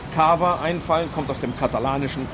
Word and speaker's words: Cava: Der Cava. 4 Buchstaben, in allen Sprachen genauso ausgesprochen, als neue Bezeichnung für den Champagner aus Katalonien.